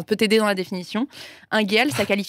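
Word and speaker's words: unguéal: Ingéal. – Waouh. – C'est l'inverse de désigual. – Ingéal. Ingéal. – Ça s'écrit U-N-G-U-E-A-L.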